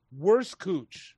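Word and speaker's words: Wurstküche: Worst Cooch. – Worst Cooch. – Yeah. – Spell that for me. – It's like W-U-R-S-T-C or K U, and it's got like the German dots over the U or whatever.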